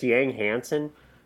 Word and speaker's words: Yang Hansen: Isn't it just Yang Hansen? – Yes, yes, it is.